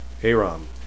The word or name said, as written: aram